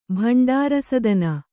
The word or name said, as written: ಭಂಡಾರಸದನ